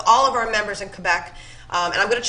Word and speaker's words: Quebec: I know in English we say Quebec. Did I say that right? Quebec? Quebec.